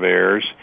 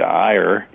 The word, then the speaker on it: Eyre: They called it Ayer, by the way. That's the real pronunciation of E-Y-R-E. And then I had an uncle, bless his soul, a great uncle, who thought he'd make it more phonetic by changing it to Iyer. And he failed because everyone spells our name I-R-E or calls us Irie or Erie or whatever.